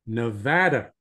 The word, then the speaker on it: Nevada: And I have to pronounce that one properly because my son lives there now, and every time I screw up, he corrects me. So Nevada. Tom is a psychotherapist, marriage and family therapist, and a licensed clinical alcohol and drug counselor.